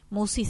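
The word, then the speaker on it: Musci: En la semana subimos los programas filmados al canal de YouTube que lleva mi nombre, Marisa Musi. – Marisa Musi. – Musi se escribe M-U-S-C-I y la lista de reproducción es Serenamente.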